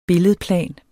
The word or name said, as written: billedplan